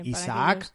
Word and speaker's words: Isaac: Sí, Isaac como en español, pero también luego Cates se escribe C-A-T-E-S.